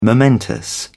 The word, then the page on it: momentous: British English